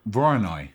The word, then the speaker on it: Voronoi: Voronoi.